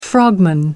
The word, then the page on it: frogman: American English